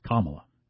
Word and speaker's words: Kamala: For Biden and Kamala Harris, because it's pronounced Kamala. – Right. Like the punctuation mark in La.